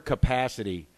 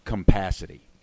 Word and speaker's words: capacity: My most recent principal, the word capacity was pronounced compacity. – Compacity? – Yeah, with an M, compacity.